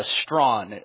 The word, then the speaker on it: Strachan: What does the Bible teach about transgenderism by, Strawn, although it's spelled Strachan, Owen Strawn.